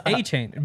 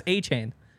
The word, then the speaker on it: Achane: It's Achane. Be unconfident in him. Trust me, it's Achane.